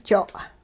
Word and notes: jo': hupa

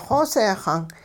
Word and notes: xooseexang: hupa

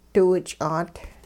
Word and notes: diwich'aat: hupa

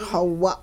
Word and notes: xowa': hupa

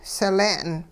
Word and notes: sile'n: Hupa

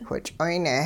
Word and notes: which'oyne: hupa

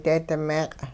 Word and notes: deedi-meeq': hupa

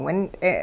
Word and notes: wint'e: Hupa

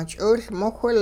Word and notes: ch'iwilmowhil: hupa

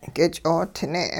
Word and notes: k'ich'oodne': hupa

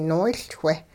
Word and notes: noowiLchweh: hupa

